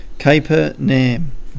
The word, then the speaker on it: Capernaum: I don't know if that's how you say it. Say it again. – Capernaum. – Oh, that's it. I knew you were saying it wrong.